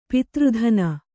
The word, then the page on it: ಪಾತ್ರದಾನಿ: pātra dāni